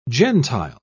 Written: gentile

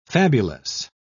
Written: fabulous

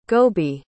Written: gobi

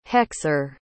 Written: hexer

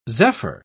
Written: zephyr

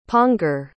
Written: pongor

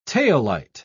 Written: taillight

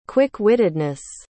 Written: quick-wittedness

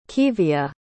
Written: kevia

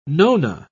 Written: nona

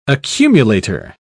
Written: accumulator

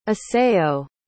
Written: asayo

